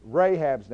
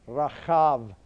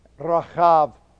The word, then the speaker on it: Rahab: And so we have the same thing in Rahab's name.